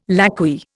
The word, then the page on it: lacquey: Indian